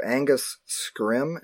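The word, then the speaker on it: Angus Scrimm: I have on my list here Angus Scrim. Am I pronouncing that correctly? – Yes.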